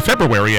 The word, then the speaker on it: February: Getaway Friday, February the 2nd, or February as it's supposed to be pronounced.